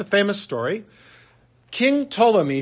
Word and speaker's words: Ptolemy: And King Ptolemy, it's PT, so the P is silent.